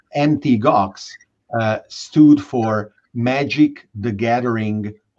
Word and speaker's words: Mt. Gox: As an illustration of your point, that the gamers flocked to Bitcoin, MT Gox, which is not Mt. Gox as some mistakenly pronounce it, was one of the first Bitcoin exchanges, then famously went bankrupt.